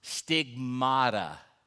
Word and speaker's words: stigmata: That is the word that's translated marks, but it's pronounced stigmata.